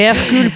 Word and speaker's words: Hercule: E Hercule Poirot.